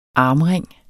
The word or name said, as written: armring